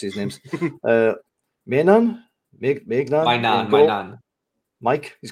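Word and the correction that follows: Maignan: It's Magnan, yeah.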